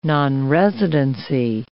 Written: nonresidency

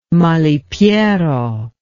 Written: malipiero

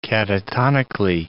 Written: catatonically

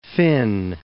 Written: Fyn

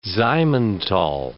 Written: Simmenthal

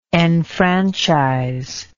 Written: enfranchiser

